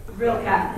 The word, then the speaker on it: Rilke: Rilke, thank you.